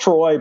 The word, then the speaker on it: Treu: It's pronounced Troy, but spelled T-R-E-U.